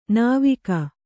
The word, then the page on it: ನಾವಿಕ: nāvika